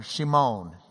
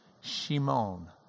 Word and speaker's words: Shimon: So he goes and he finds his own brother, Simeon, or Shimon in Hebrew is the way it would have been pronounced. Shimon, Simon, Shimon.